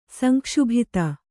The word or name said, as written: ಸಂಕ್ಷುಬ್ಧ